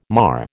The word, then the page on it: mar: American English